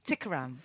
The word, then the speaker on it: Tikaram: So just to explain, back anno, we did play Twisting My Sobriety by Tanita Kneeram. Have I said it right? – No. – No. – Tikaram.